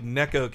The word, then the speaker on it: Neko: I love, it's actually Neko Case, like the word, the Japanese word for cat.